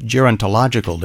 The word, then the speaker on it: Gerontological: Gerontological, did I say that correctly then? – You did.